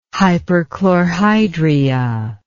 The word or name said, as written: hyperchlorhydria